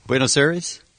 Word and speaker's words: Buenos Aires: Is it not Aires? Am I saying that wrong? – Buenos Aires? Yeah.